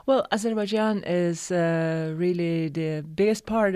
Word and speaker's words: Azerbaijan: And not that there's not plenty to talk about Manhattan, but originally from Azerbaijan.